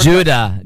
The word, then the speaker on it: Judah: We're in a different Judah, J U D A H, Gavra, G A V, like Victor, R A.